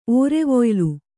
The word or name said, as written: ಓರೆವೊಯ್ಲು